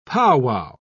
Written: powwow

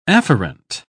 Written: afferent

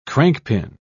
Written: crankpin

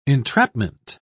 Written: entrapment

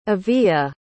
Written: aviya

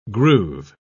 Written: groove

